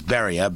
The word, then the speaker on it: Beria: And I never pronounce his name right. I think it's Lavrenti. Lavrenti. Lavrenti. But the last name is Beria. Beria.